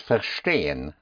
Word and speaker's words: verstehen: Let's take to understand is verstehen. Verstehen. Verstehen. Stehen, which is spelled S-T-E-H-E-N, because in German, S-T always has the sound of an S-H-T.